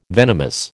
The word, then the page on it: venomous: American English